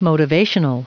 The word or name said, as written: motivational